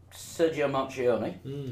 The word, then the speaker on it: Marchionne: But the head of Fiat Group, Sergio Marchionne, how's my pronunciation sounding? – I think it's pretty good.